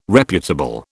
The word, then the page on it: reputable: British English